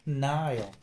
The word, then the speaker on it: Nile: Pero no se dice nada se dice Nile.